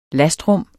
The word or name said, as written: lastrum